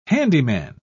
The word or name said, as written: handyman